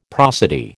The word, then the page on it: prosody: American English